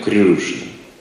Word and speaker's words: Кришна: Мы говорим Кришна. Если классический, то Кришна. Короткая А, Кришна. Классический. Как санскрит читается, я слышал так, что-то вроде этого. Если мы говорим по-русски, то Кришна. Это русский. Тот, кто санскрит знает хорошо, он скажет, ну и произношение. Еще Креста говорят где-то в Индии. Или Христос от этого слова. Ории говорят Крюшна. Крюшна.